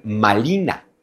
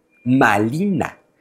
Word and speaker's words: Malina: Pero en la lengua náhuatl, el fonema R, el de la letra R, no existe. No se pronuncia en náhuatl. Entonces, ahí donde los españoles dicen Marina, los hablantes del náhuatl dicen Malina.